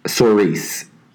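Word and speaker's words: Saoirse: Her name is Ceres. I don't know if I'm butchering that name. – I think it's pronounced differently. It's like Cerise.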